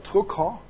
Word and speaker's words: Drucker: He was an Austrian, so his real name, or re- the uh, correct German pronunciation of his name, would be Peter Drucker.